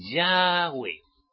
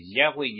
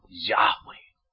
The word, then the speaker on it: Yahweh: Y la mayor parte, como digo, de personas que platicáis con moi, Yahweh, acento en la E, no es así.